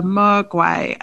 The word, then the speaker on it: maguey: So pre-Hispanic invasion, the main kinds of fibers that were used were from the yucca plant, the palm plant, and I know I'm going to mispronounce this, but the maguey, I think, M-A-G-U-E-Y. Those are the main kinds of fibers used in their weaving, and they're very much known for their weaving and their embroidery.